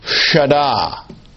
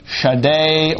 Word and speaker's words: Shaddai: And this is a long I, and it can be Shaddai or Shaddai. And I'm just going to leave it like that. Shaddai.